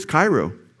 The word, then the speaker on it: chairo: It's chairo. chairo, C H A I R O is how you say it in English.